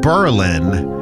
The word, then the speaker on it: Berlin: Okay, so that band is called Berlin, but the town in New Hampshire is called Berlin. And if you say it wrong, the people that live there get really upset with you.